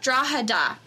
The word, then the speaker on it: Drogheda: Drogheda. So the G is silent.